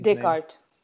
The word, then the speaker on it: Descartes: I don't know how to say his Descartes.